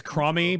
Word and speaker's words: Chromie: Chromie? – Uh, her name is Cromie, by the way.